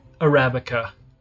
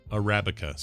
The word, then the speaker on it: Arabica: Arabica. – Arabica.